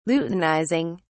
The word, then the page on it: luteinizing: British English